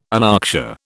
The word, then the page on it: anoxia: British English